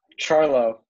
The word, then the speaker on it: Ciarlo: Wait, Jack, how do I say your last name? – Ciarlo. – Ciarlo, okay.